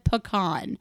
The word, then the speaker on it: pecan: Yes, I said pecan. That is the only proper way to say it.